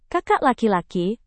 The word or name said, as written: kakak laki-laki